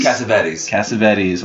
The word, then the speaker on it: Cassavetes: let's see if I can pronounce this name. Nick Cassavetes? – Cassavetes. – Well, I'm a jerk.